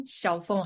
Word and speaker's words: Xiaofeng: So just search my name, Xiaofeng Harmon. Is spelled as X-I-A-O-F-E-N-G. Harman is H-A-R-M-A-N.